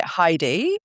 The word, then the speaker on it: Heidi: I pronounce it Heidi, but I quite like the way that you say it, to be honest.